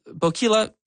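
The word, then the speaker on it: Boquila: The Bokila kind of rhymes with tequila. That's how I think of it in terms of pronunciation.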